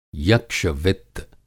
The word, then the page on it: यक्षवित्त: Hindi